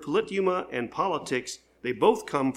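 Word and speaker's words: politeuma: Just write P-O-L-I-T-E. And then you add U-M-A. So it's polite and then U-M-A. And you pronounce it polituma.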